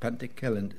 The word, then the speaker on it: Pantycelyn: Pantycelyn, is that how you say it? Pantycelyn, thank you.